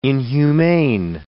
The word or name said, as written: inhumane